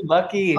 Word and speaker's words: Buc'ees: Is it Buc'ees? – Yeah, baby, Buc'ees.